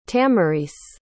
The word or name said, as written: tamarice